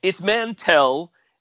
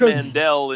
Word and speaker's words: Mantell: It's Mantell, and Harrison Ford mispronounced it in that particular take of Empire Strikes Back. – Are you kidding me? Really? – Yeah. He said Ord Mandel in the movie, but it's Ord Mantell.